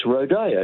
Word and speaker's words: rodeo: It's a Spanish word in origin. The Spanish pronunciation of the E in the second last letter is A, so it's rodeo.